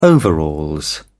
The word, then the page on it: overalls: British English